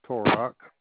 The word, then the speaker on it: Torok: Last name is Torok. – Spell it for me, please. – T as in Tom, O-R-O-K.